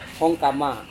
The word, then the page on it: hongkama: Serewen